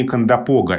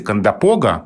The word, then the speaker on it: Кондопога: А в литературном языке более привычно ударение кондопога. И тогда вот как раз столкнулись эти два варианта. И э, при том, что словарь имен собственных Флоренции Леонидовны Агеенко говорит о варианте кондопога, как в основном, он отмечает такое побочное ударение на первом слоге. То есть все-таки там два ударения на самом деле. Просто для литературного языка основное ударение в конце слова – а для э, речи местных жителей в начале.